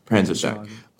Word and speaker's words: Panzerschreck: and was then reverse engineered into the Panzerkrieg, which I think I'm saying that right. – Panzerschreck.